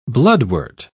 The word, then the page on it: bloodwort: British English